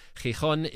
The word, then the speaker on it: Gijón: Gijón is the correct pronunciation of the Spanish town